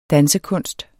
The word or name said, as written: dansekunst